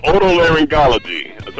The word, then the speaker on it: otolaryngology: It's pronounced otolaryngology.